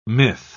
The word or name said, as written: myth